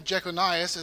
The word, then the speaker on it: Jeconias: Okay, so this man, Jeconias, as it's pronounced here in Matthew, is Jeconiah is another way to pronounce it. The Bible spells it different ways, but his name is Jeconiah.